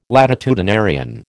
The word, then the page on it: latitudinarian: American English